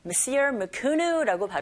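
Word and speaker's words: Mesir Macunu: Messir Mukunu라고 발음을 하고 있죠. 우리말로는 메시로 마주누라고 하는데 이것을 발명했다는 그런 이야기가 되겠습니다.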